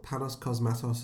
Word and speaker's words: Panos Cosmatos: Well, Panos Cosmatos, is that how you say his name? – Yeah, is clearly watched a lot of David Lynch.